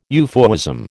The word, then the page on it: euphuism: American English